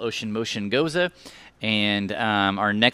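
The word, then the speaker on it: Gose: The goose. – Okay, that right there is a salted sour. So it's an old German style of beer, pronounced Goza, by the way. – Oh. – Everyone calls it that, by the way.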